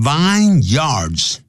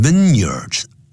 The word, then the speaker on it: vineyards: The vineyards, 不要念成 vineyards, 记住它合在一起要念成 vineyards, vineyard, 或者就念成 year, 连在一起 vineyard, the vineyards of the Cape, 这个开普省的这些葡萄园啊 are thought to be the best, 那么是被认为是最棒的啊。